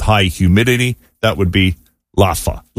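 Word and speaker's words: L'afa: And this time it's Laffa, which is easy enough. – Laffa. – Yeah, it's L apostrophe A-F-A. It's pronounced Laffa.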